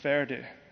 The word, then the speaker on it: Forde: It's spelled F-O-R-D-E, but you pronounce it Ferde, like German, right?